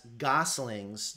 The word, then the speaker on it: gosling: I think I said goosling. I figured, okay, I screwed that all up. Gosling.